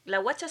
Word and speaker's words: Washa: La Guacha se escribe W-A-S-H-A.